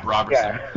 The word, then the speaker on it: Roberson: But if he's at least an elite defender, which Roberson is, it's Roberson, right? – Yeah, Roberson. Yeah, Roberson.